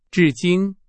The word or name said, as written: 至今